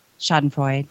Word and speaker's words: Schadenfreude: So they outsource it to the state, and they get Schadenfreude from that. I don't even know how you say that, but it's something like that. – I think you're saying it correctly. Schadenfreude.